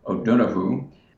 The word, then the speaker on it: O'Donohue: But just recently, it's a sad and powerful moment, but a dear friend, John O'Donohue, which is pronounced in Ireland, O'Donohue, he had passed away about a decade ago, and we had our first event, you know, to honor him.